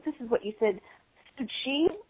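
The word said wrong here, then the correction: Tzu Chi: Yeah, it's Tzu-Chi, that's spelled T-Z-U, is one word, and the other word is Chi, C-H-I, Tzu-Chi. – Okay. – And the woman who founded it, her name is Heng Yen, H-E-N-G, and then second word is Y-E-N, Bhikshuni or Master Heng Yen.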